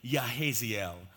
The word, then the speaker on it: Jahaziel: This man, Jahaziel, some would pronounce his name Yahaziel.